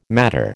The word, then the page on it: matter: American English